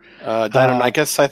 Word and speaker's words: Deinonychus: So as you were walking around and doing your stuff, you hear coming from the northwest where the Dionysus. Is that how you pronounce it? Whatever. – Deinonychus, Deinonychus dinosaurs are kind of moaning and making some sounds.